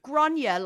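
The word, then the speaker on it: Gráinne: I remember I was saying to somebody, it's, you know, Grania, like Sonia.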